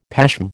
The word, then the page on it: pashm: American English